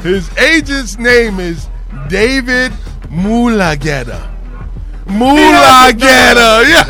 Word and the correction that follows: Mulugheta: So it's Mulugheta, but it seems like Mulageta.